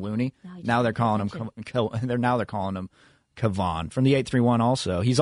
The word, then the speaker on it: Kevon: So Kevon. – It took the Warrior fan base a while. We didn't realize it was Kevon for a while.